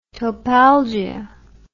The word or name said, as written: topalgia